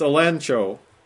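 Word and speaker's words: elencho (ἐλέγχω): And that is elencho in the Greek, E-L-E-G-C-H-O. But G-C-H is always pronounced as if it was N-C-H, because the Greeks couldn't say nch. Or gutch, so they said inch. So it's elencho.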